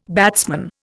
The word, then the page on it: batsman: Indian